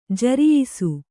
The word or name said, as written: ಜರಿಯಿಸು